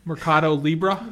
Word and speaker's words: Mercado Libre: Sandy, how do you pronounce this next one? Mercado Libre. – Yep.